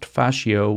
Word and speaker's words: fascio: You know, like the ropes or the strings that you would use to bind together a bundle of sticks, which in Italian is pronounced fascio, which is the root word for fascism.